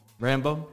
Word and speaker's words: Rambeau: Monica Rambeau. Rambeau? How do you say her last name? – Rambeau. – Rambeau.